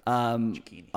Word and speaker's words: Cecchini: It's like zucchini, but chick, Dave Cecchini. – Good stuff. – Cecchini.